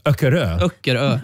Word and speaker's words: Öckerö: Ja, fjärde plats vet jag hur det uttalas, för där har jag varit mycket, kan jag säga. – Ja. – Öckerö heter det.